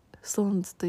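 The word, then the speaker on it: SixTONES: シックストーンズと書いてストーンズと読む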